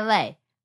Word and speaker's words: lait: Oh, almond lay. Is that right? Cafe au lait is cafe with milk, right? – Yeah. – Oh, yeah, lay.